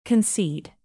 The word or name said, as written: concede